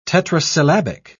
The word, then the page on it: tetrasyllabic: British English